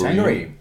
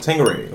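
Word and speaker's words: Tanqueray: Tangeray. Tangeray.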